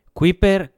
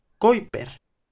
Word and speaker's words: Kuiper: Y en holandés se dice Kuiper. Pero eh, luego viajó a Estados Unidos y allí le llamaban Kuiper. Y como se escribe Kuiper, pues aquí muchos le llamamos Kuiper probablemente mal, ¿eh?